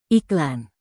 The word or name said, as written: iklan